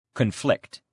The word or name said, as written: conflict